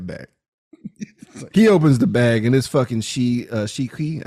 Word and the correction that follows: Shu Qi: I think it's Shi. Oh, man. I can't pronounce Chinese names to save my life. It's Shu Qi. That's the best I got.